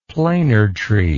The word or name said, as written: planer tree